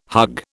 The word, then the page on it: hug: British English